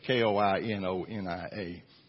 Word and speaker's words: koinonia: Now, the definition of fellowship is this it's koinonia. Uh, well, I'm, I'm, I'm going to spell it for you K O I N O N I A.